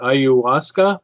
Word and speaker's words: Ayahuasca: You know, and I wrote today that, how do you pronounce it? Ayahuasca? Is that right? – Sure.